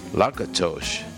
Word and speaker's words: Lakatos: Lakatos, they say in Hungarian, it's spelled L-A-K-A-T-O-S.